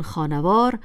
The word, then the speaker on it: خانوار: شاید شما حالا در مورد کلمه خانوار هم تردید یافته باشید و گمان کنید که آن را باید خانوار تلفظ کرد، ولی این نگرانی را نباید داشت، چون خانوار از جمع شدن خانه و وار ساخته شده است و بنابراین حرف نون آخر خانه ساکن نیست.